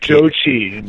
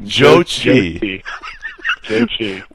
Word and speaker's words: Zhou Qi: Is it Zhu Qi? How do you say that? The Chinese guy that you have, grade Zhou Qi. – Zhou Qi.